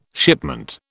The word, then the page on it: shipment: British English